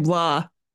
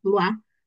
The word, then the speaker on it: Blois: I speak French, but I can't pronounce Blois. It always sounds like I'm throwing up. – I do the same thing, Blois. Blois. – It just sounds like you're throwing up.